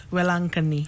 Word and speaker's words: Velankanni: In the same way, in India, we have a popular shrine called Our Lady of Velankanni. – Our Lady of what? – Velankanni.